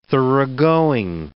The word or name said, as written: thoroughgoing